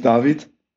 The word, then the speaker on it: David: Ja, also getauft bin ich David.